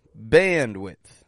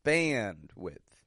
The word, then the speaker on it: bandwidth: Bandwidth. Notice that word. Very useful one. Notice how the an stretches out in band. An. Bandwidth. And again, the first syllable is stressed. The second, quick and quiet.